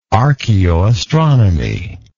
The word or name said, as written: archeoastronomy